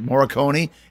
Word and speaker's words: Morricone: Morricone.